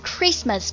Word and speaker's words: Christmas: 圣诞节，圣诞节，那么第一个词汇肯定是 Christmas, Christmas, C-H-R-I-S-T, T 不发音 M-A-S, Christmas, Christmas, 圣诞节。